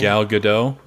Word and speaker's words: Gal Gadot: Gal Gadot? What's her name? – Gal Gadot. Yeah. – Yeah, yeah, yeah. I'm sorry, I butchered that.